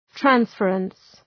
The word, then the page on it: transference: ‘trænsfərəns